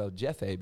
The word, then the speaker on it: El Jefe: Just don't let them call you El Jefe because there's a J in Spanish and it looks like a J. It looks like you pronounce it as a J, but it's pronounced as an H.